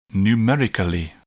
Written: numerically

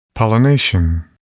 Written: pollination

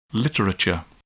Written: literature

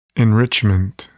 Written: enrichment

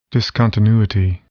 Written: discontinuity

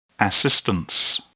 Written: assistance

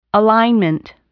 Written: alignment

